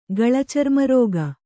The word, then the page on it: ಗಳಚರ್ಮರೋಗ: kannada; gaḷa carna rōga